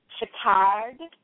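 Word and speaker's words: chakar (चक्कर): It's C-A-L-A-K-A, but it's pronounced shalak. – Okay. So the next word is busy. – Busy. Busy is chakard in Hindi, and that's C-A-K-K-A-R-A, but it is pronounced chakar.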